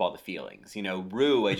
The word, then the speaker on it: Ryu: So my friend told me, who is, you know, in the know of Japanese names, that it's just Ru.